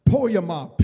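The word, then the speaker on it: poiema (ποίημα): The word workmanship means, it's a Greek word called poiema. P is pronounced poiema, P-O-I-E-M-A.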